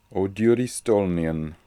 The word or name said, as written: odyuristolnian